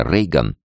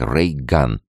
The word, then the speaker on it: Рейган: Вдобавок фамилия Рейган произносилась точно так же, как Рейган, лучевая пушка.